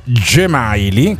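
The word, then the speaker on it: Džemaili: A proposito di mogli dei calciatori, eh, gu- cercate su Google moglie Gemaili, che si scrive eh. Zemaili, ok? – Sì.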